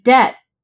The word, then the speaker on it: debt: B is silent when it's followed by what other? I mean, there's Jay's favorite word of B is in delium because it's spelled bedelium. I don't think that's what we're going for. I'm going to say T because like debt.